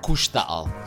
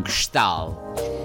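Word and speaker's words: KXTAL: qui sull'isola di Holbox c'è un nuovo negozio, diciamo nuovo relativamente, cioè avrà qualche mese di vita, che si chiama Kustal, scritto tra l'altro in maniera difficile per noi italiani perché c'è K-X-T-A-L, ma si legge Kustal, se non Kstal.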